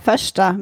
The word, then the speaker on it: första: Första. – Första. – Okay, das, so spricht man das aus.